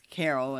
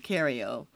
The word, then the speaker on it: Cariol: And so they put my name wrong as Carol instead of Cario, but at the same time, they still gave me an appearance ticket without ID.